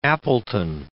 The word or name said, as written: Appleton